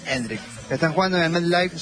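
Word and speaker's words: Endrick: Eso descarta la entrada de Endriki. – Puede ser. – Yo quería ver a Endriki. – No me estás cerrando del todo la pronunciación. – Endrik, ok.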